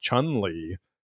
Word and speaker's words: Chun-Li: Chumley, I knew Rick Harrison. I didn't know the other. – Yeah. Chumley was how most kids pronounced Chun-Li playing Street Fighter 2 back in the day.